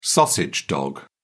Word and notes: sausage dog: British English

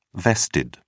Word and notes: vested: British English

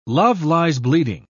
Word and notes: love-lies-bleeding: British English

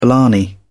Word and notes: blarney: British English

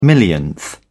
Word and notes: millionth: British English

